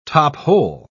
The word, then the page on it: top-hole: British English